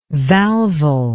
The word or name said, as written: valval